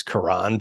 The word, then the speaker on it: Karan: Yeah, I know his name is Karan, but Karen works better, and it's spelled kind of like Karen.